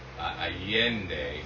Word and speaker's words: San Miguel de Allende: Okay, so it's San Miguel de Allende. So there's a double L. So it's Allende, San Miguel de Allende.